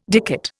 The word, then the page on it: dicot: Indian